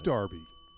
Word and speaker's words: derby: But it is still spelled D-E-R-B-Y. So why the heck would we be pronouncing that word as if it had an A in it?